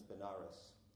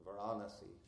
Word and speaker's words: Varanasi: So Kashi, or Varanasi, the English mispronunciation is Benares. The proper Sanskrit is Varanasi. Sometimes when I say Varanasi, it's Varanasi. We have to be careful about the shorts and longs.